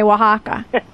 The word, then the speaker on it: Oaxaca: It took me years to learn how to say Oaxaca.